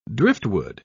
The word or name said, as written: driftwood